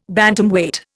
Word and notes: Bantamweight: Indian

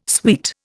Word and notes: sweet: Indian

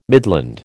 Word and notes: midland: American English

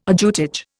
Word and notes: ajutage: Indian

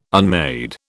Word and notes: unmade: British English

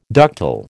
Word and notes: ductile: American English

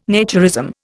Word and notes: naturism: Indian

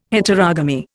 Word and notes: Heterogamy: Indian

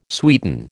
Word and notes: sweeten: American English

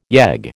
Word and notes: yegg: American English

